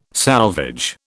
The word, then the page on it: salvage: British English